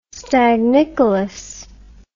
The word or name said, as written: stagnicolous